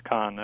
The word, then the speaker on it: Kohn: It is pronounced Kahn, yes. – Okay. – My father changed it to Kahn. Actually, my uncle's all pronounced it Cohn, so K-O-H-N normally is pronounced Cohn, so that's understandable. But now I pronounce it Kahn.